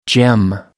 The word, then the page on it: gem: American English